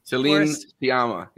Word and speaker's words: Céline Sciamma: I know I've already said that, but it's just so pronounced that I was blown away having this is the first Céline Sciamma. Is that how you say it? – Céline Sciamma, yeah.